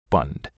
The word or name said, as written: Bund